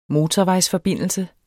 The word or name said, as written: motorvejsforbindelse